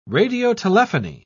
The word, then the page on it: radiotelephony: British English